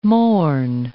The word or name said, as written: morn